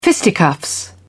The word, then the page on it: fisticuffs: British English